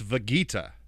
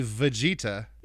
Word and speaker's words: Vegeta: He asked Fable, and he told Rustage that Fable said it's Vegeta with the hard G. Shit. And Rustage said Fable is incorrect. It is definitely Vegeta.